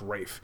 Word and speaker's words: Ralph: It's spelled Ralph, but I think it's pronounced Rafe. They had him in the Lego Batman movie playing Alfred, but they also had the Lego Voldemort character in there. And for any person that knows Harry Potter, Rafe Fiennes played Voldemort in the movie, but so he's in the movie playing Alfred, but he's not playing Voldemort.